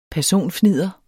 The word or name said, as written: personfnidder